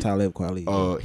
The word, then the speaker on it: Talib Kweli: And it was like for, you know, like you had people from like Public Enemy, Talib Kweli, if I'm saying his name right. – Talib Kweli.